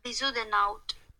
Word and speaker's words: Bezuidenhout: Bezudenout. – Bless you. – Bezudenout.